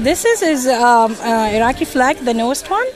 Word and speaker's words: Iraqi: You're from Iraq. – I am from Iraq, and I'm Chaldean, Christian Chaldean. – Christian Chaldean, and right now she's in a beautiful, beautiful, traditional Iraqi, did I say that right? – Yes, Iraqi top or Iraqi fustan.